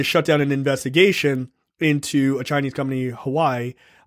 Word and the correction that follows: Huawei: Or Huawei or whatever it is, how it's pronounced. – Huawei.